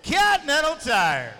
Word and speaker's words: Continental Tire: For those who do listen to the podcast, I do not believe that is how you pronounce Continental Tire. – It's Continental Tire. – That is not how you pronounce Continental Tire. That's how somebody like myself would. – All right. Is it Continental Tire? – That is correct.